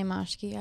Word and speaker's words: Marschke: Tamay Marshke, I don't know. – Tamay Marshke? Yeah. How do you spell that? – M-A, are you trying to like steal my identity? M-A-R-S-C-H-K-E.